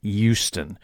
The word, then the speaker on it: Houston: I have a very good friend and colleague that was born and raised just northeast of here, and he informs me that the Texas locals pronounce it Houston. That's that the H is silent.